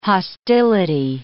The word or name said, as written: hostility